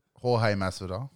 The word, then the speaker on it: Jorge Masvidal: And how do you pronounce this bloke's name again? – Jorge Masvidal.